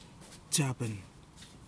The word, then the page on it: tasiapen: kwamera